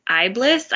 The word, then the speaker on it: Iblis: Islamic theology is rich in references to Iblis. I believe that's how you pronounce it. – Yeah, the that's exactly.